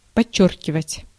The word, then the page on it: souligner: France; /su.li.ɲe/